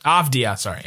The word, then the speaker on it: Avdija: Am I saying that right? Avdija? – Avdija? – Avdija, sorry. Yeah. I put an extra syllable in there.